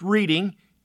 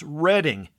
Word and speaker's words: Reading: It's like the railroad. It's not pronounced Reading, it's Reading, or in this case, West Reading, Pennsylvania.